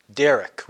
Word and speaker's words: derekh: The Hebrew word is often translated for way is derek, which is D-E-R-E-K with a silent H. So D-E-R-E-K-H.